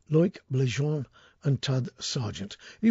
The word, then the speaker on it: Loïc: The two lads are called, and I'm going to get this wrong, I realise now, I pronounced it Loic before. It's L-O-I-C, which I think is Loic Bléjean,